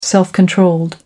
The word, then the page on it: self controlled: American English